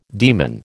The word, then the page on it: demon: American English